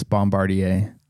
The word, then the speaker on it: Bombardier: She said it wrong, and you said it wrong. It's Bombardier.